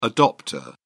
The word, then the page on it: adopter: British English